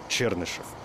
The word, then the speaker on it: Чернышев: И там, знаете вы или нет, но сейчас можно будет погордиться, россиянин Сергей э, Чернышов или Чернышев. Ой, простите, великодушно. Забыл, куда в фамилии ставится ударение. Чернышев.